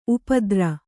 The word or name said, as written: ಉಪದ್ರ